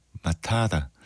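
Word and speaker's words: Matata: 네, 라이언 킹 OST에서 네이선 레인 외에 많은 출연진들이 함께 노래한 하쿠나 마타타 아니죠? 마타다. 예, T가 D 발음으로 들리는 모든 게 다 잘 될 거야 걱정하지 마 뭐 이런 뜻이라고 하네요.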